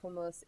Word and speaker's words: hummus: Hummus. Say it like an Israeli. Yeah. – That's right. – Say it, Israeli. – Say it right. Say it right. Say ha. You got it. You got to get it in the back of your throat.